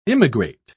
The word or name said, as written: immigrate